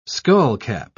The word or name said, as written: skullcap